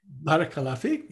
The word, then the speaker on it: Barakallahu fik: Barakallahu fik, is that how you say it? – Barakallahu fik, yeah, beautiful, thank you.